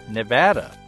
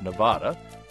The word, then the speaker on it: Nevada: It's Nevada, not Nevada.